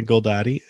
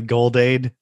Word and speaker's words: Goldade: Between you in Portland, me in Victoria, our friend Owen Chan in Calgary, and Jenna Goldadi in Red Deer. Although I'm pretty sure her name is actually Goldade. I just refuse to pronounce it like that. I like to say Goldadi.